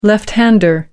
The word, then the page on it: left hander: American English